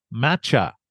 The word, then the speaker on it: Matcha: But first, we want to introduce you to our poster kitty for adoptable pets at the Humane Society, and that's Matcha. Did I say that right? M-A-T-C-H-A.